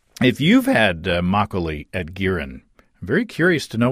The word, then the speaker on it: Girin: I did some searching online to see if anybody's making makgeolli commercially in the U.S., and I found Girin. I believe that's the way you pronounce it, G-I-R-I-N, a Korean steakhouse in Seattle.